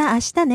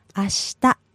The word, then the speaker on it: ashita: Mata ashita ne. – Yes. And can you give us the word for tomorrow? Break it down nice and slow. – Okay. Ashita. – Yes, and by syllable. – Ashita.